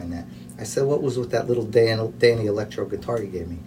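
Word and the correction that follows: Danelectro: He goes, Jimmy, it's pronounced Dan Electro.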